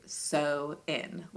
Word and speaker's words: Samhain: Because that's how it's spelled phonetically, but it is sow-in, like as in you are sowing seeds in.